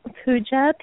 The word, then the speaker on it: Puja: Pooja, P-U-J-A.